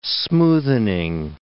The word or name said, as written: smoothening